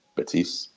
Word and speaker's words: Betis: I mean, Barcelona is a Finnish club at this point, but Man U plays Betis? Is that how you pronounce it? Betis? – Yeah. Real Betis.